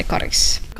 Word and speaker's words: Karis: Jag uttalar det karis. – Karis. – Karis.